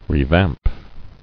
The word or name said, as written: Revamp